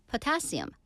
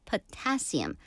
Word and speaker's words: potassium: 칼륨도 마찬가지로 potassium, 포타슘이라고 한다고 하네요. 영어 발음은 potassium.